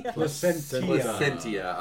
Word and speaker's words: Placentia: Well, this is made by the brewery out of what? Placenta, California? – Placentia. – I'm sorry. – That extra syllable is all important.